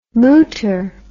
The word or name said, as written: mooter